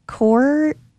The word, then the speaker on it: coir: Well, yeah, core, it's spelled C-O-I-R.